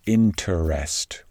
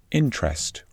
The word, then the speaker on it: interest: Well, it's not the interest rate, interest with three syllables, no, it's the interest rate. Interest with just two syllables.